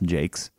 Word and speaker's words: Jacques: Because I mean, it's a whole series written by Brian Jaques, which I learned last week that it's pronounced Jaques.